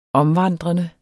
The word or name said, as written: omvandrende